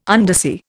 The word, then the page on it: undersea: Indian